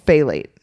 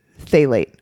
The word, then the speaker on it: phthalate: Diethyl phthalate. Phthalate. I think the P is silent. Phthalate. – Phthalate.